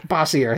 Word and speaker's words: Bossier: Meanwhile, down in Louisiana, I'm going to assume, since this is Louisiana, that that parish is pronounced Bossier, but I don't know that for a fact. – In Pennsylvania, it would be Bossier. – Bossier. Or Bossier. – Bossier, there you go.